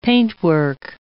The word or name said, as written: paintwork